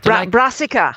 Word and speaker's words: Brassica: Or you know the brassias. Is that how you pronounce it? The brassias? – Brassica.